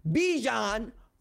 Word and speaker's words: Bijan: Bijan rhymes with Dijon because my man is all sauce.